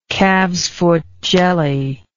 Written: calf's foot jelly

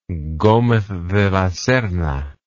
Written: gomez de la serna

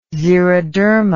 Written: xeroderma

